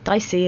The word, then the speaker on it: DSEI: Dicey is how they pronounce DSEI, which is the Defence Systems Equipment International.